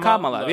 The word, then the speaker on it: Kamala: So I would just think of it as C-O-M-M-A, la. – Yeah, Kamala.